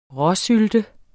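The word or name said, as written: RÅSYLTE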